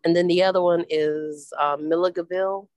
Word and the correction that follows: Milledgeville: I don't even know if I'm pronouncing that correct. – Milledgeville, yeah.